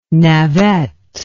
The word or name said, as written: navette